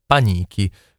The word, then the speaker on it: Panichi: E allora intanto Monica Panichi, così chiudiamo la carriera sull'accento, mi conferma che si pronuncia Panichi, quindi io ho detto Panichi, l'ho sempre chiamata Panichi, evidentemente ho una pessima memoria, scusami Monica, ovviamente so che mi perdonerai.